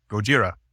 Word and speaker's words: Gojira: So they just combined gorira, kujira, and had Gojira. – And of course, Americans, we hear that and we know that the L is not part of the Japanese dialect. So it just kind of phonetically sounded like Godzilla to us.